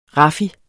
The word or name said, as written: raffi